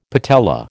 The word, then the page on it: patella: American English